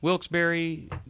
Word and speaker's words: Wilkes-Barre: Wilkes-Barre, WRGN is the station.